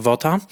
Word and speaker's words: Vogt: Van Vogta, pisze się V-O-G-T, Vogt,